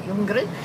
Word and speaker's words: Ljunggren: And obviously with Elaine, like, Ljunggren. – Silent L. Yep. – Silent L. Ljunggren. It's a Swedish name.